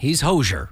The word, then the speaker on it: Hozier: H-O-Z-I-E-R. Hozier, as I'm told it's pronounced. Not Hozier, not Hozier, but Hozier.